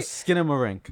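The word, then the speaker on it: Skinamarink: Oh, Skinner Mark? – Skinner Rink. I'm sorry. I'm saying it wrong. I'm sorry. – I thought you said Skinner Mark. – Oh, Skinner Marink.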